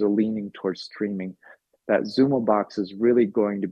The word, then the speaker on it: Xumo: And it's been really exciting that we entered into this joint venture with Comcast with this product called Xumo, X-U-M-O, pronounced Xumo.